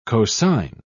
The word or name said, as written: cosign